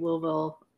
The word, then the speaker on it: Louisville: He went to the University of Louisville for cheerleading.